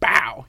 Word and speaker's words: BAWL: Yeah, because I wanted to make sure that, yeah, you know, iterated for the people that it's B-A-W-L. – B-A-W-L exclamation point. BOW. You have to say it like Avery Johnson is saying it.